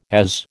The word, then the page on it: AS: American English